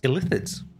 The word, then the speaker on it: Illithid: Or as Baldur's Gate 3 has told us, it's actually pronounced Illithids.